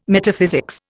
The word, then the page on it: Metaphysics: Indian